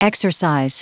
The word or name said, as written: exorcise